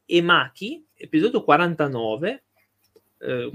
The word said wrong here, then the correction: Maci: no, sono Mici e Maci, si pronuncia.